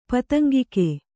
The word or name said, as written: ಪತಂಗಿಕೆ